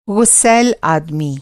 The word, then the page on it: ग़ुस्सैल: غُصَیل; Hindi